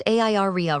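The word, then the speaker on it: ARIA: Area is pronounced AIREA, right? – Right, area stands for accessible rich internet applications.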